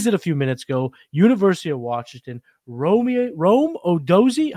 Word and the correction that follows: Odunze: How do you say his name? I'm terrible names. Odunze. – Yep. – Sounds way cooler than I said it, so thank you, Anand, for that.